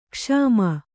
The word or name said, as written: ಕ್ಷಮ